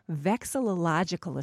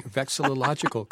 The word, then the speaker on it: vexillological: He's the secretary of the North American Vexillological Association, which is devoted to the scientific and scholarly study of flags.